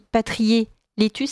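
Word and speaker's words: Patrier-Leclerc: qui est Jérémy Patrier-Létus, c'est ça, je prononce bien ? – Absolument, oui.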